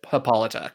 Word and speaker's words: Hippolyta: Is it still pronounced Hippolyta here? It's spelled a little differently, so I'm not... I think it's spelled differently. H-I-P-P-O-L-Y-T. I'm going to just pronounce it Hippolyta, because that's how I've always recognized this.